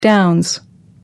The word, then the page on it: downs: American English